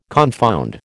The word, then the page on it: confound: American English